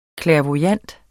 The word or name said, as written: clairvoyant